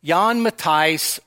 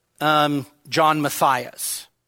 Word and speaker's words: Jan Matthijs: And you can see up there, Jan Matthijs or John Matthias, all right? That would be the anglicized pronunciation.